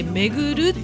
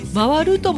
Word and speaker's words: 回る: あの回るって書いて巡るって読むんだけど、回るとも読むので、しばっち間違いではないですよ。